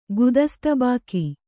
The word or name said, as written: ಗುದಸ್ತಾಬಾಕಿ